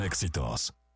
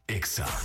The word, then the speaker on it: EXA: Y recuerda que no se dicen éxitos, se dice EXA.